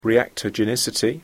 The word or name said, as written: reactogenicity